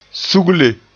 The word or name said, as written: sugle